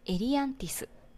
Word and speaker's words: エリアンティス: 今日の紹介するゲームは、エリアンティスです。 – はい。じゃあ、エリアンティスについて喋っていこうと思います。 – はい。はい、あ、よかった。発音合ってましたか？ – 合ってる。